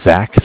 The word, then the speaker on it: Zach: My name is Zach, spelled Z-A-C-H.